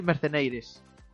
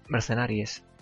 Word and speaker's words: Mercenaries: El Resident Evil Mercenaires. Y para que se, evita. – Mercenaires. ¿Mercenaires? ¿Mercenaires? ¿Cómo se dice eso? – yo qué sé. – En inglés. – Mercenaries.